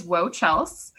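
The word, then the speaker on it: Woahchels: My name for everything is WoChels, but I've been told it's spelled weird. It's W-O-A-H and then C-H-E-L-S.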